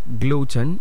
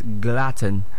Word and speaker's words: glutton: So the word is not gluten, it's glutton. That's the way it's pronounced.